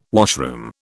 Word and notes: washroom: British English

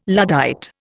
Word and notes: Luddite: Indian

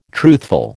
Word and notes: truthful: American English

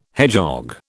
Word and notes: hedgehog: British English

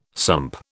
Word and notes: sump: British English

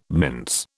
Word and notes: mince: British English

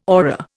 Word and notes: aura: Indian

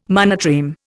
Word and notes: monotreme: Indian

